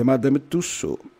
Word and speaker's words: Madame Tussauds: Oh, agora eu falei certo. Eu esqueci que eu falava francês, então por isso que eu falei errado. Mas é Madame Tussauds.